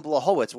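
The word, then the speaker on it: Błachowicz: Jan Blachowicz, or I'm sorry, did I say it wrong? Blachowicz? – Yes, Jan Blachowicz.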